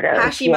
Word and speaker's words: Hashimoto: And the last topic I wanted to talk to you about is, and I'm probably going to say it wrong, but Hashimoto, is that right? – Hashimoto.